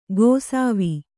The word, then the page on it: ಗೊಸಾವಿ: gosāvi